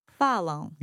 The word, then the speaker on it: falam: Falam. – Yeah, so important to stress the initial syllable, fa. Falam.